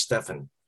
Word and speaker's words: Stephen: I actually worked with somebody for 9 years at Nike whose name is Stephen, or looks like Stephen, S-T-E-P-H-E-N. And then towards his retirement, he finally said, oh, by the way, my name is actually pronounced Stefan.